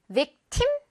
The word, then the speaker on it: victim: victim? 이렇게 발음하지 않아요. victim. 거의 뜸. 우리 밥 뜸들인다고 할때그 뜸처럼 발음이 됩니다. 워낙 강세가 없다 보니까요.